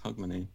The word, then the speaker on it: Hogmanay: Can we come for Hogmanay? How do I pronounce that? – That was very close, actually, yes, Hogmanay.